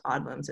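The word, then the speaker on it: Odlum: And then I think the last name is pronounced Odlums. It's O-D-L-U-M.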